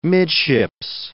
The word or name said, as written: midships